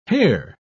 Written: hare

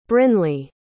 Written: brinly

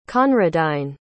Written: conradine

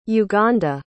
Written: uganda

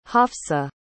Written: hafsa

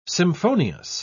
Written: symphonious

